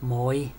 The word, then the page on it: servus: German